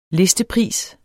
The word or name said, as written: listepris